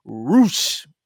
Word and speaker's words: Rush: But we started off with the AEW Worlds or Interim Worlds Championship match between Roosh, even though it's spelled Rush, versus John Moxley.